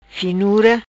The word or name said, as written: Finura